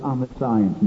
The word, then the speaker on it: omniscience: When I spell that, just put omniscience in the garret. It's pronounced omniscience. But you can spell it Om the Science, and you always get the right, you know. Om Nine and Science.